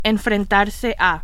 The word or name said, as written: enfrentarse a